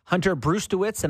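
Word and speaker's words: Hunter Brzustewicz: Hunter Brustewitz, and I'm certain I'm not saying that right. – No, it sounds okay.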